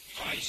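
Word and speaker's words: Rijks: Reich, ça se prononce comme ça.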